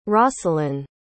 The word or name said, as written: rosselyn